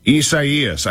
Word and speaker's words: Isaias: I say Eus. I think that's how you say it, but I wasn't sure, so I went to the pronunciation guide online, and it has this option. – Isaias. – How about this one? – Isaias. – Isaias. I think that's actually right.